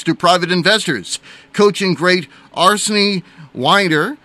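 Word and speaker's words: Arsène Wenger: And correct myself on the pronouncing of the coaching great, Arsene Wenger was his name there.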